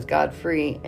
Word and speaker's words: Godfrey: but My last name is Godfrey, and it's spelled G-O-D-F-R-E-Y, and Comfrey is spelled C-O-M-F-R-E-Y, so Comfrey.